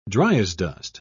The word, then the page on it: dryasdust: British English